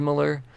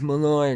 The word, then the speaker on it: Millar: I'm sorry, it's not Millar. I always said Miller. See, and then everyone's like, no, you're saying it wrong. It's Millar because it's her name.